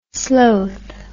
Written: slowth

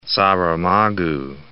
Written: Saramago

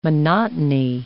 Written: monotony

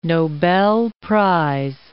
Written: Nobel Prize

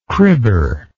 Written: cribber

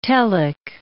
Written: telic

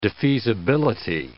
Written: defeasibility

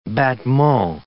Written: battement

